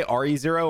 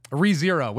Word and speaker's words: ReZero: By the way, how do you say that? Do you say RE0 or ReZero? – ReZero, which is also on my list.